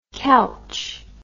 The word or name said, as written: Kelch